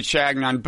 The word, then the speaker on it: Chagnon: I think he pronounces it Chagnon,